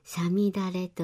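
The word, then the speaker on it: 五月雨: そうですね、はい、この時期の雨を五月の雨と書いてさみだれといい、稲作にとっては苗が育つための大切な水をもたらしてくれます。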